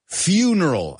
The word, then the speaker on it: funeral: Careful with that word, though. Funeral. I know in Spanish, se escribe exactamente igual. Funeral, right? Funeral. But we call it a funeral, right?